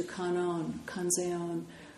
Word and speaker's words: Kanzeon: Another place they have it is in relationship to Kanzeon, which is the Japanese way of pronouncing the word that's pronounced Guanyin in Chinese.